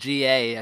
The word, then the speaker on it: GA: His name's like spelled GA, I think it's pronounced Ga.